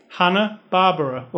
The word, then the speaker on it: Hanna-Barbera: Hanna-Barbera, like Barbara, like they're coming to get you, Barbara.